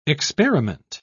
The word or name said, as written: experiment